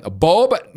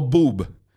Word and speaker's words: Bob: Ahora, em, Bob, no Boob.